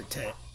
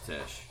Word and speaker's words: Teche: Is it Teche or Tet? – It's Teche. – Yeah, Teche. – Bayou Ami, where we were good catfishing, is spelled A-M-Y.